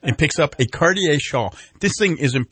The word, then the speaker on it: Cartier: Did I pronounce it? Cartier. – Cartier, yeah.